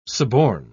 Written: suborn

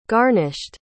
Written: garnished

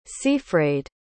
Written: seafraid

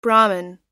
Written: brahman

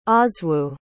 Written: oswiu

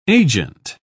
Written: agent

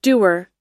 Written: dewar

